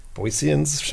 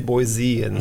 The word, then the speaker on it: Boiseans: Did I say that right, Boiseans? – Boiseans, okay. So, as far as I know. As long as I say Boiseans. – Yeah, there's no Z in there.